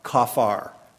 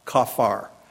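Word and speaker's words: kafar (כָּפַר): When you transfer the Hebrew word into English, it's spelled K-A-P-H-A-R, kafar, kafar.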